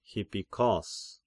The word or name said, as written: Hippikos